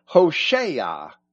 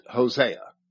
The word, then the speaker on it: Hoshea: In Hebrew, Hoshea means Savior. You are familiar with it as the name of a prophet who wrote one of the books of the Old Testament. Our English translations pronounce it Hosea.